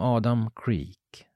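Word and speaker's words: Adam Kreek: Som Adam Creek, som han uttalar sitt namn nu.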